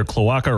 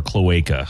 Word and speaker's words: cloaca: It's either cloaca or cloaca. I've heard it pronounced both ways.